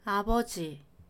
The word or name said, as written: 아버지